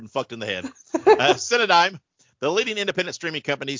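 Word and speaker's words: Cinedigm: Synodigum? I'm not sure how you say it. Synodigum? – Synodigum. – The leading independent. Yeah, Cynodyme. There you go.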